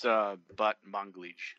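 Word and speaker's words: Batmanglij: We know that the main actress, Brit Marling, is also the creator of the show, along with her writing partner, Zal Balmontage. Am I saying it right? – It's Balmontage. – Balmontage.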